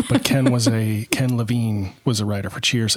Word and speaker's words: Levine: but I'm just making up pronunciations now. The guy's name is Ken Levine.